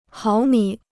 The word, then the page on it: 毫米: háo mǐ